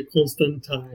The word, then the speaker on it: Constantine: Eu fiquei incomodado com a Joanna, o fato de chamar ela de Constantine. – O próprio Neil... – Não foi assim. – O próprio Neil falou que não, esse é o jeito certo de falar.